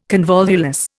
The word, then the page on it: convolvulus: Indian